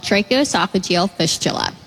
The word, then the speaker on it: tracheoesophageal fistula: It's called tracheoesophageal fistula.